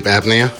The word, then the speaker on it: apnea: Plus sleep, plus fucking sleep apnea, I'm saying it wrong. – Sleep apnea? – Apnea, yeah. Like